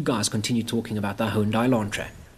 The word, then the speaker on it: Hyundai: Hyundai. It's supposed to be like Monday. Hyundai.